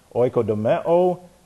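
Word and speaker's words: oikodome (οἰκοδομή): You have oikodome, O-I-K, and then we have O-D-O-M-E, oikodome. This is O-I-K-O-D-O-M-E, and that's a long E on the end. The eta is always long.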